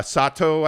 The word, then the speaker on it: Sotto: Um, and I know I missed it, but uh, somebody was talking about uh, Kai so- uh, Sotto, I believe is the way you pronounce it, S O T T O.